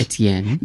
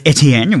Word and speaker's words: Etienne: How do you pronounce that? – Etienne. – Yes, Etienne. Well done.